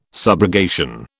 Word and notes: subrogation: British English

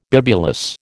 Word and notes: bibulous: American English

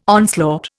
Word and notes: onslaught: Indian

